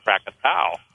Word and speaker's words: Krakatoa: was Krakatoa. Some people pronounce it Krakatau. – Yeah. – But the son of Krakatoa is called Anak, A-N-A-K.